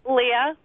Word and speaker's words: Lea: Leah. – Leah, how do I spell it? – L-E-A. – L-E-A, no H on there, huh? – No H.